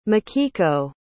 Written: mikiko

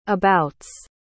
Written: abouts

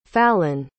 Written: falon

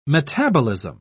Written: metabolism

